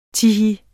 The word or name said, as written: tihi